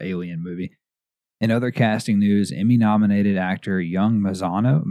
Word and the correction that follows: Mazino: Mazino, excuse me,